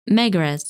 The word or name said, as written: Megrez